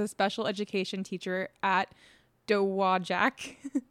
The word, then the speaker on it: Dowagiac: Pronounced Dowagiac, Michigan. Dowagiac, Michigan. Yay.